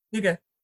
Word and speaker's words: hygge: And I know people have been a little bit obsessed with Denmark over the last few years because of the, is it hygge? I can never say it right. – Hygge.